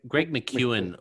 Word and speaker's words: McKeown: Spelt McKeown. So I may be, as I said to you before we press record, I may be Australianizing it, but yeah, that's how I pronounce it.